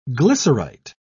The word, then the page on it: glycerite: British English